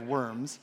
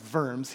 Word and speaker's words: Worms: It is not the diet of worms. It was in Germany. It is pronounced worms.